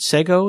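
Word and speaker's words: Segoe: Is it Sego it's pronounced? – Oh, yeah.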